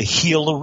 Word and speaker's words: Gila: Well, they have a river there called the Gila River, spelled with a G, G-I-L-A.